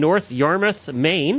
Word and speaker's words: North Yarmouth, Maine: I own and operate a small boarding and lesson facility in North Yarmouth, Maine.